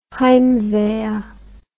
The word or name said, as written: heimwehr